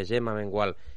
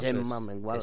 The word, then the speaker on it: Mengual: Me han recordado los mejores momentos de Gemma Mengual y Ona Carbonell en el dúo de la sincronizada española. – Qué bien pronuncias el catalán, ¿eh? – Bueno, claro. – Gemma Mengual.